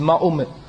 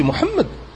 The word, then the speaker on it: Muhammad: Ils disent pas Muhammad, ils disent Mahomet, alors que ça n'a rien à voir, c'est Muhammad. Tu n'as pas besoin de le traduire, et si tu veux le prononcer en français tu dis Muhammad.